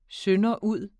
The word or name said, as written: sønderud